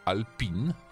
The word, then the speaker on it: Alpine: że przecież Renault wróciło do Alpine, bo tak się powinno wymawiać e, tę markę, mm.